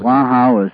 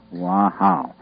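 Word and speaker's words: Hòa Hảo: That's spelled Wahao is H-O-A. Hyphen H-A-O, Wahao, is the way it's pronounced.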